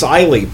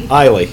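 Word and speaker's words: Eylee: Oh, my name's Eiley, by the way. – Eiley? – Eiley, yep.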